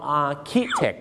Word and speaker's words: architect: ออกเสียงว่า architect นะ